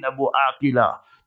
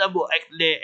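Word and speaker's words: Abu Akleh: One of the most famous Christian journalists, Shirin Abu Akila, not Abu Akle Akle. You must know the Arabic pronunciation.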